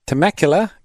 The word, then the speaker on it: Temecula: All right, we've got Steve listening from Temecula, California.